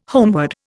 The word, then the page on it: homeward: Indian